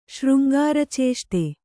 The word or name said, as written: ಶೃಂಗಾರಚೇಷ್ಟೆ